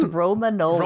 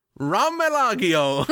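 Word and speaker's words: Romagnola: Pronounce the breed name. It's Romanola. – Romanola. – What was the very first stab at that you took? – Romelagio. I got to the middle of that and went like, oh, we gotta go up, and then that's all.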